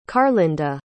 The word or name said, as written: carlynda